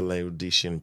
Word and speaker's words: Laodicean: Why does Jesus present himself with four different titles in the Laodicean church? And I might be butchering that name, but... – No, you said it right.